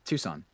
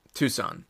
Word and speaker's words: Tucson: Tucson. – Tucson.